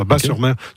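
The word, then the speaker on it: Batz-sur-Mer: On prononce Bas, le T ne se prononce pas. Bas-sur-Mer.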